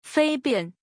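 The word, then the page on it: fabian: Chinese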